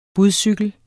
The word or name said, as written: budcykel